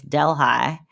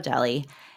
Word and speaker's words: Delhi: So this next story comes to us out of, I think, is it pronounced Delhi? D-E-L-H-I. I know here on the west side it's Delhi, but I think in India it's Delhi. Is that right? – I think so, yeah.